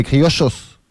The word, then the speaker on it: criollos: Un témoignage mais en tout cas, je sais qu'en Argentine, le double L... On prononce ça « ch ». Donc c'est les criochos.